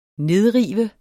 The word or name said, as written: nedrive